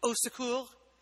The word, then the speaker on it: au secours: I believe the French term for help is au secours. – Yes. – Right? It's three syllables.